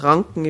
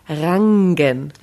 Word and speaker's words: Rangen: Ja, also in Tirol gibt es das Wort Ranken. Ich weiß nur nicht, was das da drin tut. – Wie schreibt man das? – Richard Anton Nordpol Gustav Emil Nordpol. Rangen.